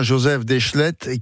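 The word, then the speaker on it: Joseph Déchelette: Venons-en maintenant, justement, alors je vais le prononcer comme vous le prononcez, vous. Venons-en à Joseph Deschlettes, qui était cet homme qui avait un lien très fort avec l'archéologie française, me semble-t-il.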